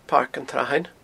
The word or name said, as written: Pairc an t-Srathain